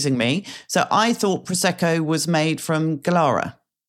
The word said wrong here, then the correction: Glera: Glera, that's right. – However you want to pronounce it.